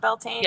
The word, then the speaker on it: Beltane: Like, the whole Beltane, is that how you pronounce it? Is it Beltane? – Yeah.